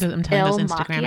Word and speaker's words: Il Makiage: It's Il Makiage.